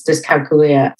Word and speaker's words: dyscalculia: The right pronunciation is dyscalculia.